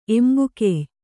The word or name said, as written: ಎಂಬುಕೆಯ್